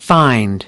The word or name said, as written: find